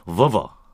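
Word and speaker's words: Volvo: 네, 정확한 영어 발음은 버버.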